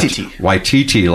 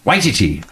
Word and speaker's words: Waititi: They said the accent on the first syllable. I would have thought Waititi, like Waikiki, Yeah. But it's Waititi, I think.